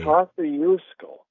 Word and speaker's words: Kosciuszko: It's pronounced Kosciuszko, starting with a K. – Kosciuszko.